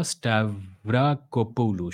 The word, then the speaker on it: Stavrakopoulou: You should really look up the work of a historian, or rather an anthropologist called Francesca Stavrakopoulos. Francesca Stavrakopoulos. I don't know how to spell it. Let me just search myself. Not Polos, Polu. Francesca Stavrakopoulou.